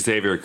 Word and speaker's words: Xavier: And at the age of 20, married band leader Javier Cugat. – Xavier Cugat.